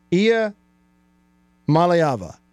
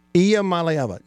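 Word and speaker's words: Iamaleava: Okay, the correct pronunciation, easy for me to say, the correct pronunciation, save that one for the soundboard, is Ia Malayava. Did I get it? Ia Malayava. Ia Malayava.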